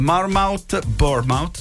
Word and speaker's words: Bournemouth: Ieri c'è stato il pareggio del Manchester City sul campo del Bournemouth, spero di pronunciarlo bene, sì.